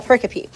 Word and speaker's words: pericope: Okay, I said that wrong earlier. A pericope.